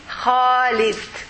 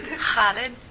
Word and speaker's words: Khalid: When you say, for example, Khalid, do you know how to pronounce this word in Arabic? Kha.